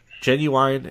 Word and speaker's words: Ginuwine: Why don't you go to Ginuwine's Pony? Ginuwine's what? – Genuine, and the song's called Pony. Genuine Pony. – Oh, I see it. It's spelled ridiculous.